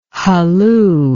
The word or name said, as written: hulloo